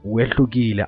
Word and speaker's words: Wehlukile: Now, since we also are going to play two of your best songs, one of them being Wehlukile, I think I pronounced it right, Wehlukile Awulunganisi. – Yes.